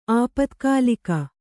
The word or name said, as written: ಆಪತ್ಕಾಲಿಕ